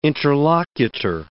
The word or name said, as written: interlocutor